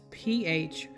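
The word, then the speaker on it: phragelloo (φραγελλόω): Further, on page 50, there is another insight box, and it says here, scourged is translated from the Greek word phragalo, and I'll spell that. It's phragelloo. Again, that's phragelloo, from which we get our English terms flagellate or flagellum.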